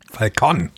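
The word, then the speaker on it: Falcón: Ich glaube, sie wird so ausgesprochen. – Falcon. Ja, stimmt, ist ja Mexiko.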